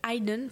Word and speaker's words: Haydn: D'ailleurs, justement, j'ai pu vérifier la prononciation. Il s'agit donc bien de Tchaïkovski pour le romantisme, mais également de Haydn pour la musique classique.